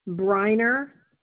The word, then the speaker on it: Briner: Briner, B as in boy, Riner. – Brinner? – Just one N.